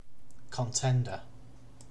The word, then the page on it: contender: British English